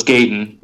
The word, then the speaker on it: Gaten: But as a kid, and portrayed by, and I should have looked up how to pronounce his name, is it Gaten? – No, it's Gaten. Yeah, yeah. You got Gaten.